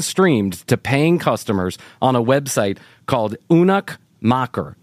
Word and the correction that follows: Eunuch Maker: and I think I'm saying that correctly. – Eunuch Maker. – Eunuch Maker. Oh, Eunuch Maker.